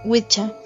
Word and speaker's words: Wicca: La tercera es la inglesa, Wicca, que se pronuncia Witcha,